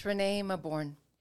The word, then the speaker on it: Renée Mauborgne: Yeah, it's Renée Mauborgne.